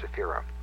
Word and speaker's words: Sephira: I believe that even in the Sephardic pronunciation of Hebrew, where the accent is on the last syllable, that in this particular case, the accent is on the next to the last. Sephira will be is the correct pronunciation, or the best one for the purpose.